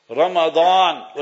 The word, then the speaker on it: Ramadan: They may pronounce it wrong. Ramadan, with a dot.